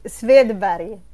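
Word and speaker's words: Swedberg: Pues es, e, e, muy fácil. es Svedbari. Williot Svedbari. No, es muy fácil si no, eres de, de Suecia o los países escandinavos.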